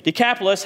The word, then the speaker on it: Decapolis: And he went away and began to proclaim in, in the uh, forgot how to pronounce this word. Decapolis? Decapolis? Thank you. Decapolis, how much Jesus had done for him, and everyone marveled.